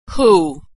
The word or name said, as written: who